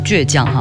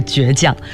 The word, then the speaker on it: 倔强: 但是在歌里面呢他们会唱倔强哈，但是从字典正确的发音来说，这首歌的这个词的发音应该是倔强。